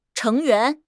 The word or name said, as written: 成员